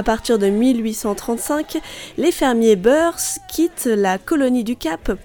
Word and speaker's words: Boers: Autant vous dire qu'avec les beurs, les, boers, les boers, non les Beurs, les Beurs, oui, on prononce comme ça les Beurs,